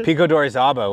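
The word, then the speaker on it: Pico de Orizaba: Pico d'Orizaba was the most recent one just in February just now.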